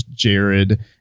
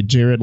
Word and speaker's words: Jared: So when I introduce myself, Tracy, this is not necessarily voice, but I always introduce and say my name is Jared. And to females, I'll say Jared like the jewelry store. And to the guys, I'll say Jared like the Subway guy.